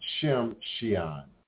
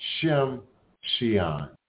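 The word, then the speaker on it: Tsimshian: I believe it's pronounced Shimshian. Shimshian.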